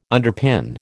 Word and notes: underpin: American English

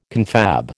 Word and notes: confab: American English

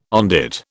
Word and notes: on dit: British English

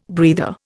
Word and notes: breather: Indian

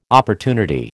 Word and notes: opportunity: American English

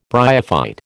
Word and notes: bryophyte: American English

